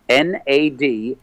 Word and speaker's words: Nad: And, to make sure that no one gets the name wrong, it's not Ned or Nat. It's N A D S Y L V A N dot com.